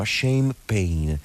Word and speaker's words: shame pain: Lui lo chiama shame pain, si pronuncia come lo champagne,